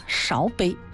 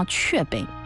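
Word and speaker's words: 芍陂: 安丰塘它古代有一个名字叫雀碑。哎呀，不好意思啊，要跟大家来坦白一下我的错误。第一次碰到雀碑这两个字的时候，有不少的主持人其实都读错了。我当时读成了勺啊，勺碑。后来有咱们非常热心的听众朋友就提醒了，这叫雀碑啊，是雀碑。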